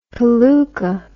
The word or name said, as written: paluka